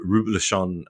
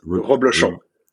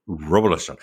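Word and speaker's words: Reblochon: Um, now, tartiflette is with the Roublechon, um, I can't pronounce it. – Roublechon. – Roublechon.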